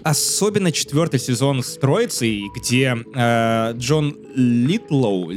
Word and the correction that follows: Литгоу: Литгоу, извините, я немного путаюсь его фамилия, э, играл...